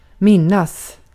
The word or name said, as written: minnas